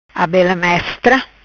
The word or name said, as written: Abela-mestra